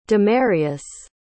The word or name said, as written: demarius